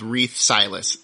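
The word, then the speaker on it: Reath Silas: Reeth Silas.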